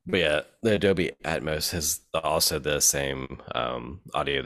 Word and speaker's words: Atmos: Why do you keep saying Atoms? – Atoms. – It's Atmos. – Atoms. Oh, is it Atmos? Oh, fuck. I pronounced it the wrong whole time, dude.